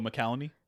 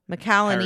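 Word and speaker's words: McCallany: Holt, how do you say his last name? – Dickinson? – McElhenney. – Oh, McElhenney? – McElhenney.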